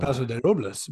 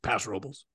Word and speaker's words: Paso Robles: Paso de Robles, but when it's cultural, like just kind of local nomenclature. Yeah, it's just Paso Robles.